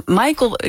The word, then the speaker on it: Michael: Michael, ik vroeg het even van tevoren. Michael Berg is jouw pseudoniem, hè? Dus ja, op zijn Amerikaans uitgesproken, die eerste naam.